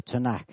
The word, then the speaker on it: Tanakh: We pronounce it the Tanakh.